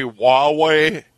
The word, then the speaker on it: Huawei: And, of course, I don't know if you've heard about the new, I think it's pronounced Hui, that big company in China. – I think it's actually Huawei.